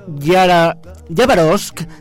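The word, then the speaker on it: Yabarovsk (Хабаровск): Una mujer dio a luz en la ciudad de Yabarovsk, que esa es la pronunciación correcta en ruso soviético,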